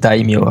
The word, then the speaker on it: daimyo: donc ils prononcent daimyo, mais ça prononce euh, pas daimyo, c'est daimyo, hein. parce qu'il y a un A hein.